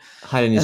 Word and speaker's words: Hainanese: You know, the Asian food Twitter got upset because some woman shit talked Hainanese chicken. Am I pronouncing that right? – Hainanese.